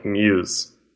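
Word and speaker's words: mus (μ's): And but they go by mus. So it's that Greek symbol with an apostrophe S, and it's spelt like M-U-S-E, or muse, like an idea or like group thing.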